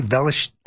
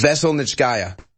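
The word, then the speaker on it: Veselnitskaya: Velish. I know he's mispronounced this. – Vezelnitskaya.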